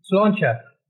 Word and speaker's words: Sláinte: How do you say in Irish, slaint? – Sláinte. – But what would you say? Sante in French. – Sante, yes.